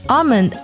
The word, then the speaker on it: almond: 那么杏眼的英文呢，恰好有这样的一个英文，它读作 almond eyes。 almond 这个单词呢拼作 a l，不过这里呢 l 是不发音的，就是读作 r 的音，然后后面呢加 mend。